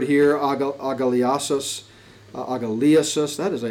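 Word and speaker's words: Agaliasis (ἀγαλλίασις): I always get the accent wrong. Agaliasis. Agaliasis is the proper pronunciation.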